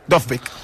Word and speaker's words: Dovbyk: Ell mateix ho va dir, Artem Dovbyk. – Dovbyk.